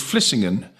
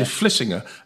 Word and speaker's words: Vlissingen: By the way, in the Netherlands, they don't pronounce the N at the name on any names. Like, I live near a town called Vlissingen, as you would say, but they say Vlissingen, and they don't say Verstappen, they say Verstappen.